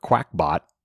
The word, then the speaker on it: Qakbot: Qakbot, also known as Qbot or Quackbot, which is the way I was pronouncing the other one as well. Anyway, it's weird. The other one's spelled Q A K B O T.